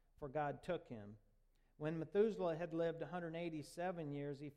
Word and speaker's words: Methuselah: That's pronounced Methuselah.